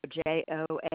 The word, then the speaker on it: Joan: Yes, Joan Block. – June is spelled J-U-N-E. – No, Joan. – Oh, Joan, I'm sorry.